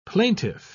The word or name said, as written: plaintiff